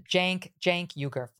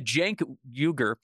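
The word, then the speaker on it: Cenk Uygur: Jenk. Jenk Uger. You pronounce it Jenk Uger. With apologies to right. Mr. Uger, because I've been butchering it here.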